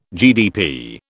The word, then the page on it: GDP: British English